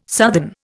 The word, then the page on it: southern: Indian